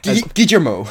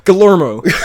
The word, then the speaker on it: Guillermo: Guillermo. – Guillermo.